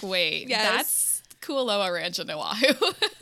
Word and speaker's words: Kualoa Ranch: So it was filmed at Kualoa Ranch.